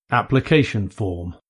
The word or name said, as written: application form